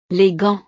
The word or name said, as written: les gants